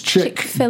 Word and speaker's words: Chick-fil-A: It's Chick-fil-A. Dash fill, F-I-L, A.